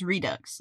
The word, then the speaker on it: Redux: I'm pretty sure it's just Redux, yeah. – Okay, because that was what I thought when I was a kid, and then somebody lied to me about how it was pronounced, and I've been uncomfortable since then. – What did they say? – They said it was like... – Redux? – No, they said it was like French, so you don't pronounce the X, and I was like, that like seems redo?